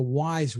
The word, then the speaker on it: Wyze: And for only $20, there's one called the Wise Watch. Looks pretty sophisticated. It looks great, but it sells for only $20. It's spelled W-Y-Z-E.